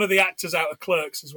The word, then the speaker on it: Clerks: hard for me because I always want to call it Clark's because it's pronounced Clark's in the UK. We don't pronounce yeah. it Clerks.